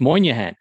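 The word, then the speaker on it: Moynahan: I hope I'm saying that right, because when you put this down on paper, I thought you'd misspelled her name. – No, I didn't. – But it's not. It's Moynihan.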